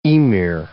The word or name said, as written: Ymir